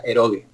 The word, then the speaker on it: eroge: Y lo que tú comentabas se llaman eroge. Bueno, los llamarán eroge por ahí, pero si lo pronunciamos en japonés sería eroge.